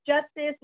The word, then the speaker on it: Justice: It is spelled C-E-A-H as in Harry. And my last name is Justice, like the Justice League.